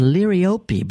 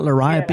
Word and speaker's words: liriope: It actually is supposed to be pronounced liriope because it's named after a Greek nymph or something like that. It's not liriope, yeah.